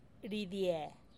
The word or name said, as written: ridie